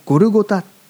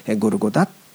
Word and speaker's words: ゴルゴタ: ドクロというのはゴルゴタという、発音をするんですね。ゴルゴダの丘と呼ばれるのは、そのためです。開きませんけれども、マルコの福音書にはその、ゴルゴダという発音で、地名が書かれてあります。